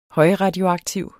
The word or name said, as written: højradioaktiv